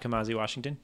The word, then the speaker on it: Kamasi Washington: Yeah, I think long songs are always interesting just to see how, I mean, like I was asking about Kamasi Washington.